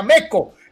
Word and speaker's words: Meco: Este, ese era el nombre real, pero pues este, eh, eh, en español se pronuncia Meco.